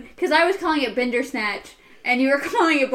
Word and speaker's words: Bandersnatch: We still have to do Boulder Dash. I know, I said it wrong. I thought about it for a second, too, and just still said it wrong. Bandersnatch.